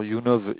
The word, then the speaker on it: YouNov: Alors YouNov, YOUNOV, Ouais.